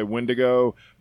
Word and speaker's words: Windigo: For tonight's topic, the Wendigo, or Wendigo, we have to, the reason I, by the way, anybody that's wondering why I say Wendigo, because the actual native, the Algonquin pronunciation or spelling that we have when we first translated it to our mutilated English was W-I-N-D-I-G-O, which is right. – Wendigo. We, somewhere or another along in time, as English, threw an E in there and called it Wendigo, putting the emphasis on the second syllable.